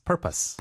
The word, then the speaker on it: purpose: purpose. – Purpose. – Purpose. – 그렇죠. 어우 발음이 아니라 으 발음으로 purpose라고 하게 됩니다.